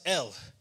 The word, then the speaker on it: El: It was just pronounced El.